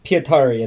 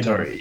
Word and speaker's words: Pietari: And for our central family, Pieter and his father, I don't think I said his name right. – Pietari, and then Piparin. – Pietari, yes. Right, gotcha.